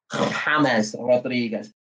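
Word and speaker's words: James Rodríguez: James, James, James Rodriguez. That's how you pronounce his name, mate.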